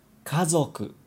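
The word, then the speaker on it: 家族: Kazoku.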